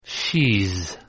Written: she's

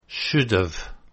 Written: should've

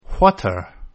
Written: what're

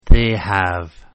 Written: they have

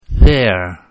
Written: they're

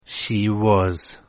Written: she was

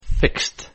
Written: fixed

